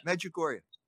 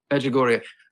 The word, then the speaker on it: Medjugorje: It was an interview you did at, I'm sorry if I can't pronounce it, Medjugorje? How is it pronounced? – Right, Medjugorje. – Medjugorje.